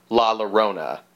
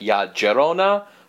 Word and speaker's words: Llorona: So we're sort of jumping back in time again for a little continuity insert episode with La Llorona, which I'm actually saying that wrong. – Oh. – Because a double L in Spanish is a Y, like in tortilla.